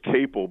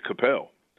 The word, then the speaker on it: Capel: This is a guy that worked for this cookware company, and our last name is Capel, but the way it's spelled, a lot of people would pronounce it Capel.